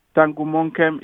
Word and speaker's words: Tangumonkem: Yeah, Eric is E-R-I-C. Tangumonkem is T-A-N-G-U-M-O-N-K-E-M.